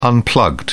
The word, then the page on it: unplugged: British English